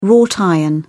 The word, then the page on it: wrought iron: British English